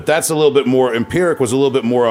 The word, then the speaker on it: Empyrrhic: And something I wanted to talk about too was I noticed that you had a project this year, if I pronounce it right, Empyrrhic. – Empyrrhic, I think. – Empyrrhic for the listeners.